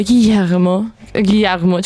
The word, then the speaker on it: Guillermo: Quindi cominciamo con Cabinet of Curiosity di Guillermo, con l'accento spagnolo, Guillermo, ce l'avranno la R moscia in Spagna?